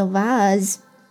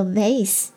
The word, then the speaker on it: vase: 好，这里呢顺带来说一下花瓶的读音，它呢有两种读音，那英式读音叫做 vase，美式叫做 vase，我个人呢当然是读作 vase， OK， vase， vase 都是花瓶。